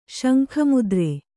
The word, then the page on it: ಶಂಖಮುದ್ರೆ: śankha mudre